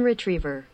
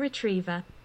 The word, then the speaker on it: retriever: Golden Retriever。 – Golden Retriever。ね、 Golden Retriever。じゃあ次にイギリス発音で言ってみますね。 – Golden Retriever。 – Golden Retriever。そう、イギリスっぽいね。 ver って伸ばさずに、 r を発音せずに golden、 retrieve v。そう、アメリカだったら ver、 ver。